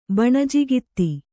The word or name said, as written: ಬಣಜಿಗಿತ್ತಿ